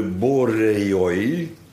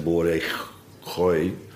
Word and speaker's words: Boreoi: Wij kennen ook bovendien de Boreoi. Borei, maar nou wordt de A-in weggelaten, niet uitgesproken. Anders zou het zijn Boregoi of zoiets, Boregoi.